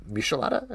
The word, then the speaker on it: Michelada: He made Michelada. Is that how you pronounce it? – Yeah. – Like Michelin star.